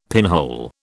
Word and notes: pinhole: British English

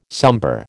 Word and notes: sombre: American English

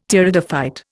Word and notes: Pteridophyte: Indian

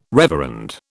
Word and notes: reverend: British English

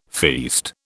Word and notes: feast: British English

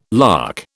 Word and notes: lakh: British English

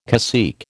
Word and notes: cacique: American English

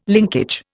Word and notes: linkage: Indian

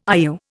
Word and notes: IU: Indian

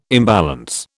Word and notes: imbalance: British English

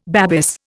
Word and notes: Babis: Indian

